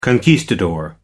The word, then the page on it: conquistador: American English